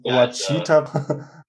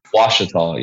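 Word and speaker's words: Ouachita: I think you can pronounce it, because I would pronounce it Wachita. – Washita, yeah. It's like a silent W, but yeah, Washita. It's an Indian name, Native American.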